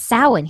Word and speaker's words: Samhain: And my boyfriend kept asking me, what is Samhain? And I'm like, that's Samhain. He's like, that's Samhain? Why is it spelled like that? I'm like, because it's Gaelic.